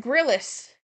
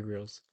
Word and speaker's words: Grylls: would have said Grillis. – Bear Grylls.